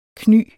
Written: kny

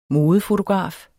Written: modefotograf

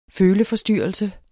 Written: føleforstyrrelse

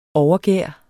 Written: overgær

